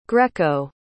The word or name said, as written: greco